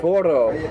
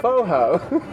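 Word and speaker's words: forró: I feel like going foro, but the pronunciation is foho.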